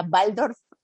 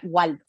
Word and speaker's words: Waldorf: Si escuchan es nada más como es la W, entonces la pronunciación alemán sería Waldorf, en mexicanismo sería Waldorf.